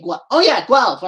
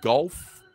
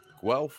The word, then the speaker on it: Guelph: Being from North America, I didn't know if it was Gulf or Guelph. – It's Guelph, isn't it? – Guelph. – Guelph, like elf, but with a gua. – Guelph.